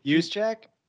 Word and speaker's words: Juszczyk: Juszczyk.